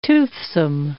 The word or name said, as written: toothsome